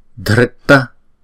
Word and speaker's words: Dhritta: Chữ D và chữ H ghép lại chúng ta đọc là Dh. Chữ R dấu dấu nặng chúng ta đọc là Ri. Ri. Chữ này chúng ta đọc là ta. Dhritta, dhritta.